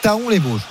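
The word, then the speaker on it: Thaon-les-Vosges: Et je précise, précision ultime donnée par Thierry sur le direct studio, on dit bien Taon, mais la prononciation exacte, donc c'est Taon les Vosges. Voilà, comme ça on met fin au débat.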